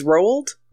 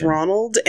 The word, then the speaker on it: Roald: I always think it's Ronald, and then I'm like, no, there's a letter missing. – No, it's not Ronald. There is no N.